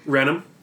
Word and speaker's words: Raynham: Raynham.